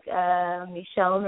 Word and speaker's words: Michone: I am on Facebook, Michonne Reese. – How you spell that? – M-I-C-H-O-N-E.